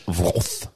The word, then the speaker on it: Wroth: Oui, mais j'ai W-R-O-T-H. Wrath.